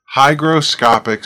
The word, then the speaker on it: hygroscopic: Hygroscopic starts with a high, H-Y, but it's pronounced in the beginning like hi.